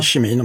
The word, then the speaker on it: Cimino: Och Michael, nu blev jag lite osäker på efternamnsuttalet. Cimino? – Ja, jag säger Cimino, men det kan Cimino, vara... Cimino, ja, det låter bra. Ja. Cimino säger vi det här.